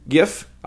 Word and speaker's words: GIF: You can go find the Paula White kind of compilation GIF. I will say it as GIF because it's a hard G.